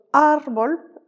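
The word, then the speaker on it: árbol: On le retrouve par exemple dans le mot arbol, accentué sur le A, qui veut dire un arbre, ou au contraire El Café, accentué sur le dernier E, qui veut évidemment dire le café.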